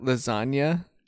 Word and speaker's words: lasagna: lasagna and lasagna. – He was like, actually grammatically that does not make sense in terms of the silent G because the G makes the nya sound for lasagna.